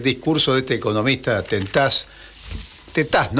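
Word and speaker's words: Tetaz: Martín Tetás, o sea, el acento en la última, en la A, digamos, para, para no confundirnos.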